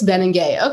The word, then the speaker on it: Benengay: Gosh, since we started, in the pandemic, and right after it kind of started, we started working together, and I've been pronouncing your name Benenga for like a year, and now I'm like, just before we jump on this podcast, it's Benengay. Okay, that sounds cooler than Benenga.